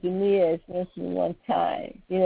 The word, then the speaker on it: Jinniya: It's the Jinniya, And it's spelled J-I-N-N-I-Y-A, Jinniya.